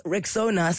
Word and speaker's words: Rexona: It's pronounced Rexona, spelled R-E-X-O-N-A.